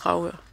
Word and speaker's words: Dragør: De skriver, at vi udtaler Dragør forkert. – Dragør? – Dragør. – Dragør. – Men jeg mener altså, det er Dragør.